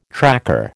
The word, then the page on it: tracker: American English